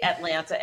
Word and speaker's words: Atlanta: But then again, Jarvis allows me to say Atlanta and pronounce both T's.